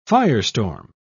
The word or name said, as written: firestorm